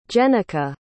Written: jennica

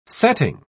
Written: setting

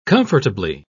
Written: comfortably

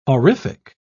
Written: horrific

